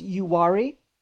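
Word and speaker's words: Uwharrie: is it pronounced Uwari? – Yes.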